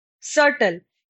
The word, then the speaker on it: subtle: और यहाँ पे प्रोनाउंस आप कैसे करेंगे, सर्टल. यहाँ पे जो बी जो है ना बच्चों, वो साइलेंट रहेगा.